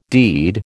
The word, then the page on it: deed: American English